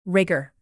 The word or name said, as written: rigor